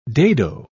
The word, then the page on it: dado: English; British English